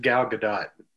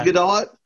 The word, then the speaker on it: Gadot: Her name is pronounced Gal Gadot. – Fuck yeah. – Oh, it is Gadot? – Yep.